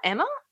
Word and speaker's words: Ema: Um, Emma, Emma, is that how you pronounce it? – E M A.